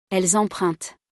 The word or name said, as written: elles empruntent